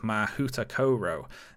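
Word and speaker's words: Mahoutokoro: For instance, the Japanese school is called Mahutakoro. Now that might not sound right to you, but that is the pronunciation guide given on the website.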